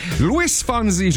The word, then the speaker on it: Luis Fonsi: Luis Fonsi, j'espère bien le prononcer.